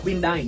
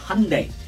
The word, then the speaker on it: Hyundai: người Việt đọc là Hyundai nhưng trong tiếng Anh đọc khá là khác đó nó đọc là Hyundai Hun giống kiểu honey đây giống kiểu ngày ấy day